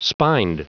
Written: spined

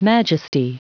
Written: majesty

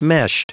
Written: meshed

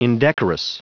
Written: indecorous

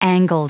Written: angled

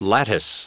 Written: lattice

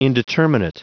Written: indeterminate

